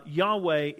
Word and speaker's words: Yahweh: How do we praise him? The conclusion that I come to is that Yahweh is the proper way.